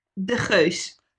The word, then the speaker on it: De Geus: De Geus.